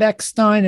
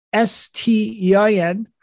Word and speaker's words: Eckstein: It's Bob Eckstein, and it's spelled Eckstein.